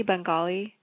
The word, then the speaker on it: Bengali: Am I saying that correctly, Bengali? – Yes, you are.